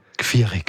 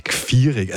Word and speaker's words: Gfiareg: Geschrieben Gfiareg, ausgesprochen Gfiereg. – Gfiereg, also praktisch.